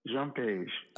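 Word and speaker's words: Jean-Page: Is it Jean Page? – Jean Page.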